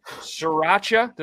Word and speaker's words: sriracha: So the flavors of jerky are garlic teriyaki, sriracha.